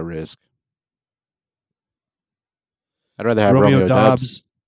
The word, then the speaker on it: Dobbs: He literally said it's pronounced Dobbs, but he doesn't care how you say it.